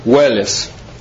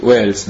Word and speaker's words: Wales: Вот он буквально, если буквам пишется Уэллес, W-A-L-E-S, Уэллес. То есть они говорят, что это вот по английской фонетике Уэльс, да?